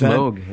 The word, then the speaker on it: Moog: It's Moog. It's pronounced Moog. – Is it pronounced Moog, really? – It is pronounced Moog.